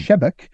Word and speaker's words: Sebeok: by a semiotician called Thomas Shebek. He spelled Sebeok, but it's pronounced mm. Shebek, uh, who uh, had this idea that the solution would be to institute what he called a nuclear priesthood, mm.